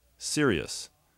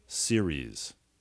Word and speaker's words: series: Chciałbym jeszcze zwrócić uwagę, że słowo series nie wymawia się serious, bo to znaczy poważny. Prawidłowa wymowa to series.